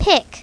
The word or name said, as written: pick